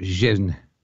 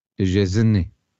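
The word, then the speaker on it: Szczęsny: Me decían que esa SZ, la primera tiene que ser como shh, y luego era como Chesney. Y acaba como un E, no en Y.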